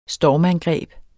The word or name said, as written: stormangreb